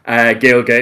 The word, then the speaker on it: Gaeilge: Gaelga is the Irish word for Irish. That is spelled G-A-E-I-L-G-E, then bear, like the animal, just because, again, I like bears.